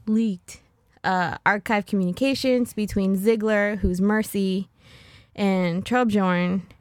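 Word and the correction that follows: Torbjörn: I'm not saying that properly. – Torbjorn? – the little guy with the turds.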